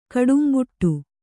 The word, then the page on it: ಕಡುಂಬುಟ್ಟು: kannada